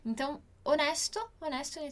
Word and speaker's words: onesto: Legal aqui que a gente tem onesto no italiano e onesto no português, que tem a mesma pronúncia, são palavras muito parecidas, mas a escrita, a grafia é diferente.